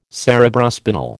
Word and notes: Cerebrospinal: American English

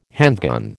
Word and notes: handgun: American English

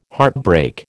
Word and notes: heartbreak: American English

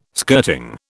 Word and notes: skirting: British English

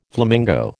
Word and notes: flamingo: American English

